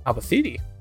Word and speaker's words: Albacete: Albacite? Let's check. Albacete. A city in Spain. How do you say it though? – Albacete.